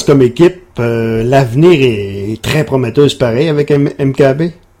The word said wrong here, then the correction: Mbappé: Mbappé, excusez-moi, je l'ai mal prononcé. – Mbappé, ouais.